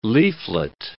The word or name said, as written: leaflet